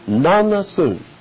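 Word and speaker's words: Nanna-Suen: N-A-N-N-A. And it's spelled S-I-N, but we phonetically will put it S-U-E-N. Nanasun.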